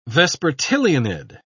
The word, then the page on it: vespertilionid: British English